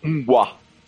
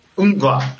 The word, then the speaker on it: Unguá: Como é que pronuncia? Como é banda, de novo? – Unguá. – Unguá.